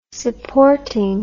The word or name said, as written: supporting